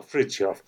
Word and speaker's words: Fritjof: What about the first name, though? – Ah, Fritjof. So Fritjof Sean.